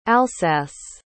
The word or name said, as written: alsace